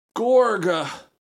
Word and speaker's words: Gorgh: Gorga. G-O-R-G-H. Gorga. How would you pronounce that? Gorg? – Yes.